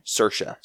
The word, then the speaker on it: Saoirse: Saoirse. – Saoirse. Saoirse Ronan. That's who our money's on. – I've watched so many interviews with her talking about her name. – Okay, Saoirse.